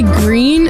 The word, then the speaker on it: Gruene: Is it Gruen Hall? – I think you say Green. That's Green how hall? – We said. – G-R-U-E-N-E? Green hall? – Yeah.